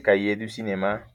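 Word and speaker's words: Cahiers du Cinéma: Calle du Cinema. Esa es la manera correcta de pronunciarlo entonces, como, como que son todos estos fans de la literatura y de la crítica, porque pues también son escritores, ¿no?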